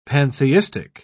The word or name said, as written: pantheistic